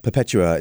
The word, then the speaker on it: Perpetua: Yeah, Perpetua is how you would say it in Latin.